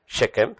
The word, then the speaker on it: Shechem: That's how you pronounce it, right? Shechem.